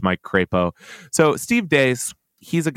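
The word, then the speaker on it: Deace: Now, I suspect his name is pronounced Deese. He insists it's Dace.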